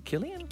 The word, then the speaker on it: Cillian: Cillian, I never know how to say his name. Cillian Murphy. – Killian? – Yeah, he's hot.